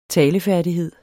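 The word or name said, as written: talefærdigheder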